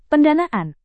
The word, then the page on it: pendanaan: Indonesian